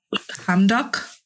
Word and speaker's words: Hamdok: One of which included the ousting of the country's prime minister, Abdallah Hamdok.